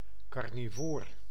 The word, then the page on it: carnivoor: /kɑrniˈvoːr/